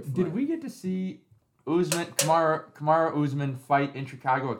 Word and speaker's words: Kamaru: I'm going to butcher this name for sure because I don't know how to pronounce his first name. – Usain Bolt. – Kamaru. – No, it's Kamaru.